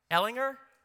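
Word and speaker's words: Ehlinger: Am I saying that right? Ellinger? Okay, I got it right.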